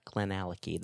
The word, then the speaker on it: Glenallachie: Yep, Glenallachie. That's how you pronounce it. – Okay, Glenallachie.